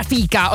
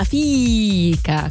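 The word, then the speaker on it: fika: E anche fica, non si pronuncia fica, ho scoperto, ma fica con 6000 i.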